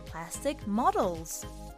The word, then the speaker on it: plastic model: plastic models それでは簡単ですが今日は発音に注意しながらみんなで言ってみましょう Repeat Plastic Model 発音は Plastic で上がって Model で下がるをイメージしてみてくださいもう一度 Plastic Model Sounds good!